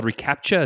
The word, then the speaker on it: reCAPTCHA: And so implemented, is it called reCAPTCHA? Is that how you say it? – Mm-hmm. Yeah.